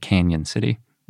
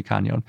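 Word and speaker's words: Cañon City: I think some people pronounce it Canyon City. – Mm-hmm, I've heard that. – But it's got an enye, so it really seems like it should be Canyon.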